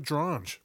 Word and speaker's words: Drange: Guild, founded by our man Alfred Drange. Remember? Rhymes with orange, Drange.